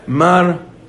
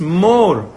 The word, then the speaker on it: Mor: Modern Hebrew, when you call somebody Mr. something, so you say Mar Ploni. That's a mistake. Mar in Lashon HaKod, this is not a Hebrew word, right? This is an Aramaic word. In Aramaic, it's Mor. That's the proper pronunciation of the word.